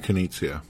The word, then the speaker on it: Knizia: Is it Nitsa? Is that Reiner Knizia? – Yeah, that guy. Yeah. I never know how to say his name.